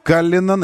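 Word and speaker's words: Cullinan: Каллинан, именно так нужно произносить название кроссовера Каллинан.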